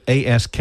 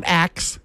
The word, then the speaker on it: ask: We had a discussion earlier, the ASK. How do you say that? – Ask. – Thank you. – It's not axe.